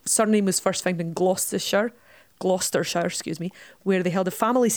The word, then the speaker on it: Gloucestershire: I hate to correct you, I believe it's pronounced Gloucestershire.